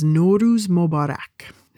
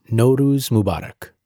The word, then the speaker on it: Nowruz Mubarak: Oh, the way you say happy Nowruz is Nowruz Mubarak. – Nowruz Mubarak.